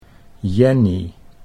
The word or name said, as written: Jenny